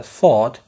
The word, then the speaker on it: thought: خد بالك إن thought بتتنطق كده thought، وممكن تترجمها اعتقدت أن أو كنت أعتقد أن، ومش شرط تحط لها أي إضافات.